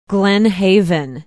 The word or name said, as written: glen haven